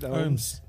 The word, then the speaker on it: Ohms: No, it's Ohm's law, isn't it? It's physics. It's electronics and yeah. – Okay. – Ohm's. – Shit band, so, but it's pronounced Ohm's.